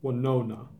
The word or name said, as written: Wenonah